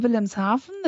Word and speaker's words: Wilhelmshaven: I'm in Wilhelmshaven.